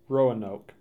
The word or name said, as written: Roanoke